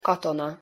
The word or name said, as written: katona